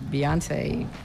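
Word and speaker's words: Beyoncé: I went to Michelle's 50th birthday party, and Beyonce performed, and that was great dancing.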